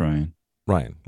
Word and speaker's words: Rian: Yeah, Ryan, was it Rian Johnson? – No, it's Ryan. – Ryan Johnson. It's spelled Rian, though, R-I.